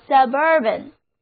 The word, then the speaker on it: suburban: 注意重音后移了，单词读作 suburban, 郊区的、偏狭的。